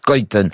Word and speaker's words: Schuiten: Et c'est là où ça a été un bonheur pour moi de rencontrer Françoise Cuitaine, ce Belge dont le nom s'écrit Schuiten, mais il faut dire Cuitaine,